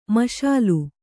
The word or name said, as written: ಮುಶಲ